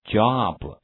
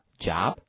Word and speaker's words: job: 那么这个地方的话，它是发短音的 o 啊，所以说的话，不要读成 job，要读的短促一点，跟我读讲 job。